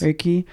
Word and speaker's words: Reiki: He also does Kemetic Reiki. Did I say that right? Yes. Reiki.